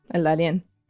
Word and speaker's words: El Darién: I just watched your latest episode where you reported from El Darién. Is that how you pronounce it? – Mm-hmm. El Darién.